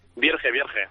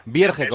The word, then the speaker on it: Vierge: Vierge. – Vierge, como se escribe, ¿no? – Sí, es un apellido de origen francés, pero él es de Barcelona y se pronuncia tal cual lo lees.